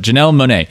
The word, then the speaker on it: Janelle Monáe: Janelle Monáe? Janelle Monáe. Yeah. Though there's an accent over the A. So for a second, I thought it was like pronounced separately. But Janelle Monáe.